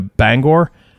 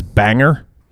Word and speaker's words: Bangor: Bangor, but he says banger nobody says banger other than people not from not from New England. It's not even spelled banger. It's spelled Bangor.